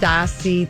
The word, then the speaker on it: Stassi: Yes, I know Stassi of Vanderpump Rules is pronounced Stassi. Thank you, Don, for telling me. But that just shows you what a pain in the ass she always was. that a name looks like Stacy and it's got to be pronounced Stassi.